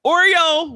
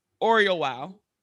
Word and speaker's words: Oriowo: So I would like to welcome Dr. Donna Oriowo.